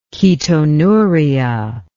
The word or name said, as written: ketonuria